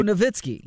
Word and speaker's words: Nowitzki: Do they pronounce it with a W? Or the W sounding like a V? – They got to be sounding like a V. – They go Nowitzki.